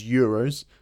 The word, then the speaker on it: euros: They don't say dos euros. They say... – Euros. – Euros. So they kind of pronounce it how it's spelt, which is a typical sort of Spanish thing to do. – Mm-hmm. – It's E-U-R, so it's E-U-R. Euro.